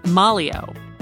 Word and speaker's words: Maglio: She refers to the psychologist as Sam Maglio, but his Italian surname is actually pronounced Malio.